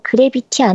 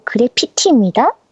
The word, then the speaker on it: 그래피티: 제아두몽님 그래비티 아니고 그래피티입니다. 그라비티는 중력이란 뜻이고 그리피티는 낙서라는 뜻입니다.